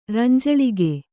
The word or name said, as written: ರಂಜಳಿಗೆ